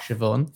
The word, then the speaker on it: Siobhan: Sayoban. Yeah. Uh, what? Siobhan.